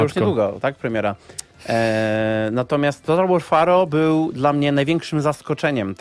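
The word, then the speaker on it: Total War: Pharaoh: Ja zapomniałem, że ta gra istnieje, więc tak, przypomnijmy, że chodzi o Total War Faro. Bo tak się to wymawia.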